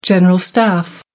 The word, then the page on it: general staff: American English